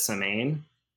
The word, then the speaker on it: Samhain: It's spelled Samhain. My great grandmother called it Samhain.